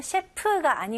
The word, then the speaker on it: chef: 제대로 하실 거면 셰프가 아니고요. 이름절로 셰프. 입술이 붙으면 안 되고요. F 발음 명확히 해주세요.